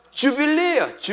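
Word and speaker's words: jubilee: 주빌리예요. 주빌리. 주라고 발음해도 되고 유라고 발음해도 돼요. 아무튼 리을 발음이 많이 붙으니까 주빌리 하면 되는 것입니다.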